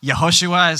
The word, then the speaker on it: Yehoshua: Yehoshua is kind of, I'm probably butchering a little bit, but that's the way it's meant to be pronounced in terms of Hebrew.